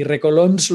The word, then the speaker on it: Recolons: Y, Recolons